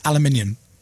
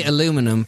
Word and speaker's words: aluminium: You know, you say aluminum, but you spell it wrong. – Oh, it's not. – It's aluminium because you... – It's aluminum. – No, because aluminium. – You miss out a crucial I.